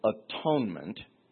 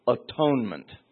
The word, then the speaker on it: atonement: atonement. You must understand that when Tyndale translated it, he didn't want you to pronounce it atonement. He coined a word, at-one-ment.